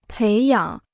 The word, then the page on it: 培养: péi yǎng